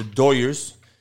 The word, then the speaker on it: Dodgers: My Dodgers, the Doyers, how we pronounce it here in L.A.